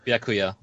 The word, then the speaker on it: Byakuya: Byakuya.